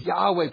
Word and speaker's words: Yahweh: It's Yahweh, probably a better pronunciation.